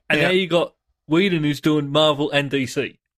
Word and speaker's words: Whedon: You said it wrong. – Is it Whedon? – It's Whedon. – But how can it be? It's there's no long E in there.